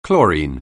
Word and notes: chlorine: anglais

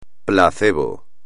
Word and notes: placebo: espagnol

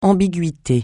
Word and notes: ambiguïté: francais

